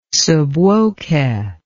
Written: sub voce

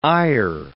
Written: ire

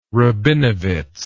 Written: rabinowitz

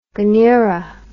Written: gunnera

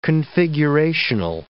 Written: configurational

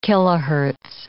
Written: kilohertz

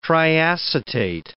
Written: triacetate

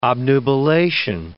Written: obnubilation

